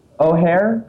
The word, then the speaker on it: O'Hare: O'Hare? O'Hare. How do you pronounce that? Do you know? – O'Hare. – O'Hare. All right, cool.